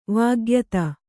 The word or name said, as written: ವಿಖ್ಯಾತಿ